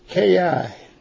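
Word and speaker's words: ki (καί): And one of them is the word and that word is K-I, and there's two ands there, both of them are pronounced in the Greek, ki, but it's the same word as even, and it's the same word as also.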